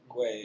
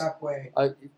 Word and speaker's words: Ngakoue: Yannick, I can't pronounce it, Ngakoue or whatever. – Ngakoue. – Yeah, I guess. – Ngakoue.